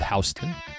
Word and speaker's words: Houston: There's a county called Houston, which is spelled exactly like Houston, that big city. – You know what? I can get the Houston one, though, because that is kind of like house.